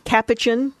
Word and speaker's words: capuchin: However, those days are over after a nine-month-old capuchin, that's the right way to pronounce that, named Jojo bit an eight-year-old boy on the finger.